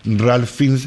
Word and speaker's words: Ralph Fiennes: Creo que el protagonista es el Ralph Fiennes, ¿no? ¿Lo he dicho bien? – Sí. Ralph Fiennes.